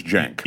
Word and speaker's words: Cenk: And so my recollection is that he knows it's pronounced Cenk. – 100% Ben Shapiro knows that your name is Cenk.